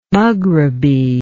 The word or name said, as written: maghrebi